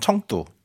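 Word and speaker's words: 청두 (成都): 네, 오늘은 우리식 발음으로는 성도, 중국식 발음은 청두.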